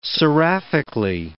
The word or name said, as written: seraphically